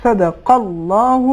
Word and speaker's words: صدق اللہ: اور آخری نقطہ آپ کی تلاوت میں یہ ہے کہ آپ نے بڑا اچھا کام کیا کہ آخر میں صدق اللہ بھی کہا, لیکن کلمہ صدق اللہ میں قاف صحیح مخرج سے ادا نہیں ہو پایا, قاف کے بدلے کاف ادا ہوا. صحیح تلفظ صدق اللہ العلی العظیم.